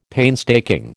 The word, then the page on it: painstaking: American English